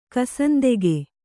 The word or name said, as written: ಕಸಂದೆಗೆ